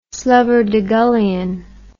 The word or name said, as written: slubberdegullion